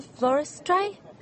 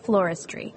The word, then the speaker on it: floristry: Retail floristry? – No. It's pronounced retail floristry.